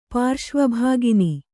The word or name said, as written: ಪಾರ್ಶ್ವಭಾಗಿನಿ